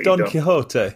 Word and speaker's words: Don Quixote: It very much reminded me of the novel, the very famous Spanish novel, which I think is called Don Quixote. I'm not sure if I'm saying it correctly. – Don Quixote.